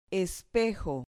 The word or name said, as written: espejo